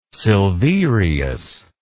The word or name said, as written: Silverius